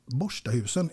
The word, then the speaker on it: Borstahusen: Du, eh, Siv Gunnel Margareta Malmqvist, född den 31 december 1936 i nordvästra delen av Landskrona i fiskeläget Borstahusen. Uttalar jag det rätt nu? Helt rätt. Borstahusen.